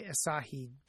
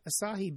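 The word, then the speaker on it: açaí: For some reason, I have been on a spam list that keeps me well supplied for offers to try Asahi berry juice to lose weight. Now, that's spelled A-C-A-I, but evidently pronounced A-S-A-I, with the emphasis on the last syllable.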